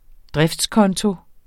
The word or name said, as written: driftskonto